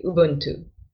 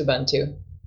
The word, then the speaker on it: Ubuntu: Okay, so it's technically, it's a Bantu word. And in Bantu languages like Swahili, it would be Ubuntu. But the Americanized form is Ubuntu.